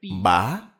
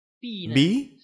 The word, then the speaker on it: bí: Chữ bả này có hai cách đọc. Bả nghĩa là đi lại bất tiện, lúc đi lại khập khiễng gọi là bả. Bí nghĩa là nghiêng lệch. Cho nên câu này đúng ra phải là vật tiễn vật, vật bí ỷ.